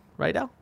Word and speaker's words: Rydell: Did I say that wrong? – Yeah. – Rydell? – Mm-hmm.